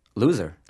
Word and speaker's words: Luzer: It's spelled L-U-Z-E-R. – So how did they pronounce it when you were growing up? – Luza.